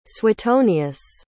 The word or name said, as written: suetonius